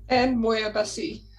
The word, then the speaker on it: Ann Moir-Bussy: I always say your name wrong. Um, can you say it, Anne, real quick for me? Ann Moir-Bussy.